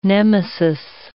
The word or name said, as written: Nemesis